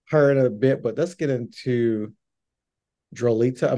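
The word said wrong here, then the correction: Drolta: I'm saying her name wrong. Drolita? – Drota, Yeah. – Drota, Duentes.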